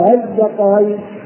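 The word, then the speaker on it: آلذكرين: فأنا بنطق بها يعني بهمزة الوصل هذه بين الهمزة وبين الألف. أقول إيه؟ أذكرين. ليس ها، لا. أذكرين. أذكرين. أذكرين. هكذا.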